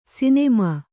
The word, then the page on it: ಸುನಾಮಿ: sunāmi